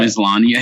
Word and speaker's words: Maslany: How do you say her last name? Maslany or something like that? – Maslany, I think.